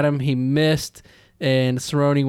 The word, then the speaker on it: Cerrone: Cerrone.